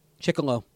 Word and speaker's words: Chiquillo: Did I pronounce it right? – Chiquillo. – Chiquillo. – Kind of like Gigolo. – Yep.